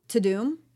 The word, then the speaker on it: Tudum: I don't know how to pronounce it. T-U-D-U-M. To Doom? – To Doom.